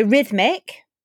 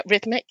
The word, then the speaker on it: rhythmic: That old silent H. – Side. Yes. So rhythmic. – Yeah, rhythmic.